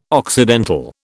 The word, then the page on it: occidental: British English